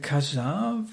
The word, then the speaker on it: Kajar: Aber wir hören jetzt das Stück Kajar, habe ich das richtig ausgesprochen, von Seda? – Mhm. Ja. Also, ja, ich.